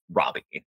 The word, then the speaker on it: Robbie: I always want to say Margot Robbie because her name is Margot, and then I want to pronounce her last name French, but she's Australian as fuck, and it's Robbie.